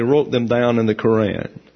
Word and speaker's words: Quran: And in the Quran, actually, is the correct pronunciation, the Quran. And so the secondary pronunciation is the Quran.